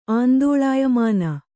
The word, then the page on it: ಆಂದೋಳಾಯಮಾನ: Kannada